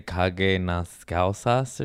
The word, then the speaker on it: cague nas calças: Yeah, and the one I dated one Portuguese girl back in the day, and she said, told me to say "cague nas calças" or something. Did I say that correctly? – Yes.